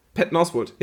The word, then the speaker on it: Patton Oswalt: Patton Oswalt? – Patton Oswalt, ja.